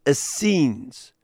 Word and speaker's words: Essenes: You may well know that there was a community of Jewish people who lived in the Holy Land, known as the Essenes. That's the Greek pronunciation. They would have been called Chasidim in Hebrew, but Greek has no letter H. They don't have that letter. So they just put it Essenes.